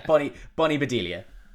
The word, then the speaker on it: Bedelia: But yeah, Bonnie Bedelia.